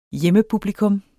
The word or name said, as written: hjemmepublikum